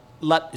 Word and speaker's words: lat: But the Germans always pronounce it wrong. They say latsao, lat. And in Cantonese, it would be lat lat it's short lat so lat yeah.